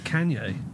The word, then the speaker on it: Kanye: Well, I always call him Kanye. – No, that is his name. – Oh, is it? Oh, I finally got it right. – Call him Kanye.